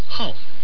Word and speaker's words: 号: 5 はハオと発音します。